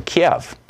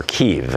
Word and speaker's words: Kyiv: Kiev. or Kyiv, as apparently they pronounce it in Ukraine.